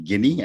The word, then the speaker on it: Ginny's: I was calling it Ginny. It's actually Ginny.